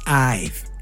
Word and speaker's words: Ive: Y ahora acaban de dar la notificación de que Airbnb acaba de contratar los servicios de Johnny Hive. Y perdón por pronunciar mal Hive, es Ive Gracias, Fer.